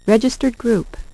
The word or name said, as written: registered group